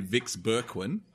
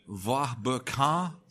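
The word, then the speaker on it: Vieux-Berquin: So it's, well, to the Aussies, it would have been Vix Berquin. And I think in French, it's something like Va Berquin, something like that. – Sounds good. I'm going to go with that. – It's V-I-E-U-X hyphen B-E-R-Q-U-I-N.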